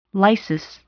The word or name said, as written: lysis